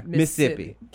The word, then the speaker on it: Mississippi: Every now and then, y'all, I get like very Mississippi. – Mm-hmm. Mississippi. – Because it's only two syllables to people from there. Mississippi.